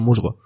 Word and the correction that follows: Musk: Elon, Elon Musgo. No, no se llama Elon Musk, el de... – Elon Musk, sí. Elon, Musk, perdón. Es que, la que de pronunciar.